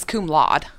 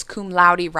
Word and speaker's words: cum laude: Also, it's loudy. – I'm like, yeah, it's loudy. It rhymes with rowdy. – What did you think it was? – I thought it was cum laude.